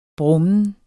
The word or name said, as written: brummen